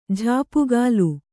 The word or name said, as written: ಝಾಪುಗಾಲು